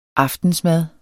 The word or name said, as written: aftensmad